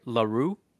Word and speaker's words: Lahru: One being, I think I'm pronouncing this right, LaRue, and the other Vader. So LaRue spelled LaHRU. So I think the H is silent, and it's just LaRue.